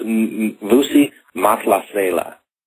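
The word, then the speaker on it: Vusi Mahlasela: Yeah, it's Vusi Masala is how it's pronounced, and he's from South Africa.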